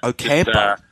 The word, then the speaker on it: Ocampo: I spoke to him earlier in the week about how to pronounce it. I thought there would be something needing doing, and he just said Ocampo.